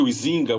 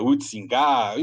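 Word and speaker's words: Huizinga: Eu chamo de Uizinga, os historiadores não gostam dessa pronúncia, é Uitzinga, enfim.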